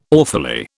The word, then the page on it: awfully: British English